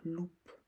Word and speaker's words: loup: Comme par exemple dans le mot loup, il va y avoir un P muet à la fin. On l'écrit donc loupe, mais on va prononcer un loup.